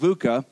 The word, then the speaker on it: VUCA: Um, it's pronounced VUCA, and it stands for four letters.